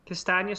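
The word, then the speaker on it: Castagne: And I got rid of Cancelo for Castagna. Am I saying that right? – Yeah, that's how I say it. – So, you know, I can't be too unhappy because Castagna scored a goal tonight.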